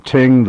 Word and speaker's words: Tyng: Also, I want to clarify something for our listeners who were interested in purchasing those books by Stephen Ting, the Low Church Episcopalian rector at St. George's in New York City in the 19th century. His last name is spelled T-Y-N-G,